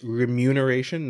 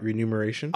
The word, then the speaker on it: remuneration: By the way, did you know that it's remuneration, not renumeration? – Oh, sorry.